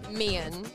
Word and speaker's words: Meehan: You also said my last name wrong. – How do I say it? – Mee-han.